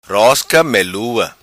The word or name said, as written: Rosca Melua